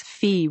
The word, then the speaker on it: fi: Sexy, I think it was kind of a joke, but the fi is actually pronounced in Portuguese fi, which is basically a kind of a slang, yay fi.